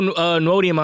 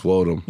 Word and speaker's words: Nwodim: Follow Ego Nwodim on Twitter. – It's Wodum. – Wodum? Sheesh. I know that. – The N is silent.